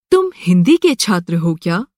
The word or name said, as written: छात्र